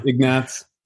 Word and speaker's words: Ignatz: Ignatz.